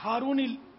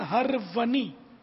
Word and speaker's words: ہرونی: یہ عموماً عثمان ہارونی بولتے ہیں لوگ, کوئی عثمان ہارونی بولتا ہے, کوئی عثمان ہرونی بولتا ہرونی پڑھتا ہے, تو یہ اصل تلفظ ہے عثمان ہرونی.